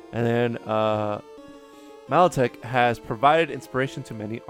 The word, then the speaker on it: Milotic: Milotic.